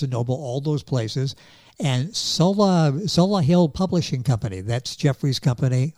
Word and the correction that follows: Solihull: You know, Solihull, it's actually where I was born.